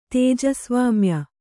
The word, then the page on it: ತೇಜಸ್ವಾಮ್ಯ: kannada